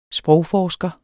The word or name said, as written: sprogforsker